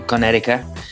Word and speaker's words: Connecticut: Eh, y, tú bien lo has dicho, es que Las Vegas Aces ha, ha tenido que sudar sangre para derrotar a, Connecticut, vamos a decirlo bien, hay que pronunciarlo bien, Connecticut.